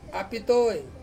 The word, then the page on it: a pitoi: Ansus